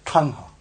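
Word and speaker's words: taṇhā: So a retroflex is actually tangha. You know, curl the tongue back on the end. Tangha.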